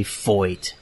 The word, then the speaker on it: Feicht: I came across him pronouncing his own name, and he said clearly Foyt, like A.J. Foyt, the famous race car driver.